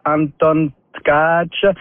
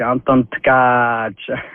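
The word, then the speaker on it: Anton Tkáč: C'est bel et bien Anton Tkach. Alors, si tu regardes, c'est écrit, c'est T-K, A avec un accent. Et C avec un crochet, le A, c'est un long A, donc c'est Anton Tkach.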